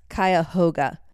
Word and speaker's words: Cuyahoga: This affected a large portion of the area near and around Boston, Ohio, which became part of the Cuyahoga Valley National Park. I'm pretty sure I said that right. – Sounded right to me. I don't know. – It's Cuyahoga. Cuyahoga Valley National Park.